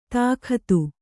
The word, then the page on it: ತಾಕತ್ತು: Kannada